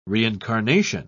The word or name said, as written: reincarnation